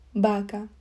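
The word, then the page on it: vaca: Spanish (Spain)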